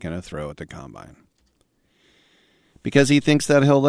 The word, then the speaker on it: combine: Remember, I'll remind you again, in football, emphasis on the wrong syllable. It's not offense, defense, and combine. It's offense, defense, combine.